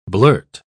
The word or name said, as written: blurt